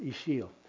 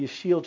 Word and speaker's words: Ixil: The letter X is pronounced as an SH in English. It doesn't exist in Spanish. But IXIL is pronounced Ishil. This is the Ishil tribe.